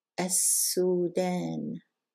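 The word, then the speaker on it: As-sudan (السودان): We're not going to pronounce the lam and we're going to double the next letter. As-sudan. Very good.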